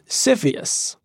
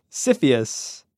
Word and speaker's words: CFIUS: So there's this body in the government called the Committee on Foreign Investment in the United States, which is abbreviated and pronounced CFIUS. – CFIUS.